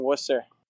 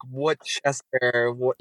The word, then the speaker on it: Worcester: But yeah, Matt works at a brewery in Worcester, kid. – Yes. Dude, you're stopping off in Worcester, dude. – Yeah, anyone who's listening, stop by at Greater Good. Out in Worcester.